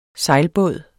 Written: sejlbåd

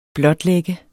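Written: blotlægge

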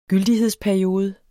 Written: gyldighedsperiode

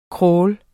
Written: kraal

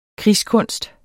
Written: krigskunst

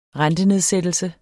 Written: rentenedsættelse

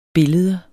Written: billede

